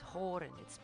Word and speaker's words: Horan: Our surname was Horan. It's spelled H-O-R-A-N. And I always thought it was Haran, or H-A-R-A-N. I always thought it was pronounced Haran.